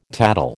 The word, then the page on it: tattle: American English